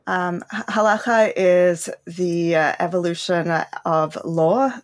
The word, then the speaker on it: halakha: You got to get that guttural cha in, but it's basically correct, halakha.